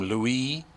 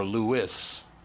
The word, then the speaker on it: Louis: What appears to still be a source of confusion, whether to pronounce the baby's name Louis, as CNN notes, or Lewis, as the son claims.